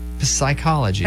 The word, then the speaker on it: psychology: The P is silent in psychology. – Yeah. – Psychology.